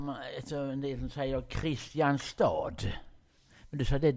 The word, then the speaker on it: Kristianstad: Eller säger man Kristianstad, säger en del. Kristianstad heter det.